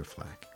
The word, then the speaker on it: FL4K: And that's Flack, but it's spelled FL4K for Flack.